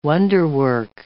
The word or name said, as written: wonderwork